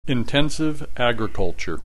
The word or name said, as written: intensive agriculture